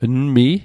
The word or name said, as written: nmi'